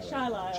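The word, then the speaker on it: Chili: And Dave Finger, Chile Town Leader. – We pronounce that Chilai.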